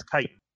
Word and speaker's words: chitin: Is it actually chitin? – It is one in every party. – It's chitin. It's or chitinous. Like. – I've heard people call it chitinous a lot. Because that's obviously how it's phonetically spelt, but yeah, it's pronounced chitinous.